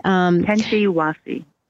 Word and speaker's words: Qentiwasi: It is Kentawasi. I hope I'm pronouncing that right. – Kentawasi.